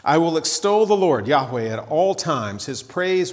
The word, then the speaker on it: Yahweh: It was probably pronounced something like Yahweh. You will occasionally hear it as Jehovah, but since there's no J sound in Hebrew, that's probably not right.